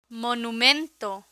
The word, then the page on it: monumento: Spanish